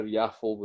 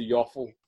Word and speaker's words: Yaffle: It's spelt like waffle, but with a Y at the start. But I feel like it would be pronounced yaffle as opposed to yoffle.